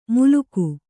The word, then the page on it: ಮೂಲುಗು: Kannada